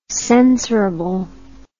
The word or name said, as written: censorable